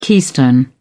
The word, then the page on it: keystone: British English